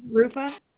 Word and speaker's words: Ruffa: It's RUFFA, Rufa.